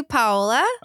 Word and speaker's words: Paola: So it's technically Paola.